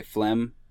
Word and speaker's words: phlegm: By the way, phlegm fucking pisses me off as a word. Silent G until it has a suffix on it.